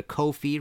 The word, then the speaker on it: Ko-fi: If you liked this show and want to support future episodes of this show, please go to Ko-fi, rhymes with no fee, .com/MartinPopoff.